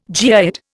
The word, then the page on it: geoid: Indian